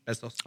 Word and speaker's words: Bezos: What happens if Jeff Bezos, you find out he's... – Bezos.